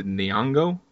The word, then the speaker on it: Nyong'o: Lupita Nyong'o. I think I pronounced that correctly. – Good um, job.